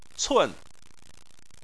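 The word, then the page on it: cun: tone 4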